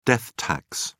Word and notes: death tax: British English

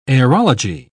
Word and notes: aerology: British English

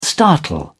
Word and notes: startle: British English

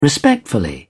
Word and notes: respectfully: British English

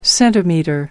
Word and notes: centimetre: American English

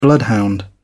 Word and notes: bloodhound: British English

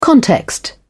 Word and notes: context: British English

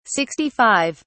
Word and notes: 65: British English

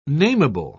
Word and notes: namable: British English

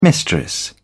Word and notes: mistress: British English

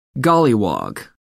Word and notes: golliwog: American English

